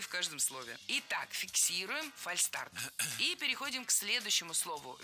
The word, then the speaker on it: фальстарт: Фальшстарт пишем и произносим без «ш». Почему так? Слово «фальстарт» пришло к нам из английского языка «fail start», то есть ошибочный старт. Никакого звука «ш» здесь нет.